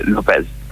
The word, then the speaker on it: Lopez: Lopez, la pronuncia corretta è Lopez, buonasera, grazie dell'invito. Sì, ha posto problemi a molti in realtà questa pronuncia, perché sembra un po' spagnolo e invece Lopez. alla francese.